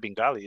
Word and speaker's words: Bengal: Is it with an I? It's not Bengali, is it? – No. It looks like Bengal, okay. like the Bengals. – Like a Bengal tiger? – Mm-hmm.